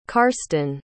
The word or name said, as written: karston